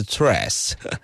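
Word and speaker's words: Threads: 那讲到了午休时间，或是中午时间，大家吃完饭或者是一边吃饭的过程当中，可能都会一边吃饭一边滑什么，一边滑 trash。对不起，我还是发音简化成脆好了。我虽然我知道它正确发音是 trash，但是因为这样子要念起来可能没那么顺畅哈。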